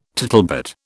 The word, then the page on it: tittlebat: British English